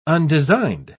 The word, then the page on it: undesigned: British English